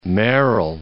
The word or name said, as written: Merrill